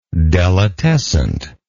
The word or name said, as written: delitescent